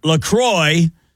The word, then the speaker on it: LaCroix: Officially, it's pronounced LaCroix, kind of rhymes like with enjoy.